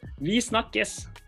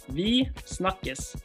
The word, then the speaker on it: Vi snakkes: Vi snakkes. – Oh, if I say it, I'm going to be like... – You gotta try. – Okay, tell me again. – Vi snakkes. – Vi snakkes.